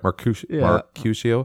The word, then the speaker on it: Mercutio: Mercutio.